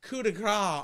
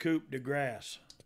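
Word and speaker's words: coup de grâce: Balor came off the top rope with the coup de grace on Cena. – Oh, yeah. – And Angle. – I think it's pronounced coup de grace. – Coup de grace, as we call it, on Cena.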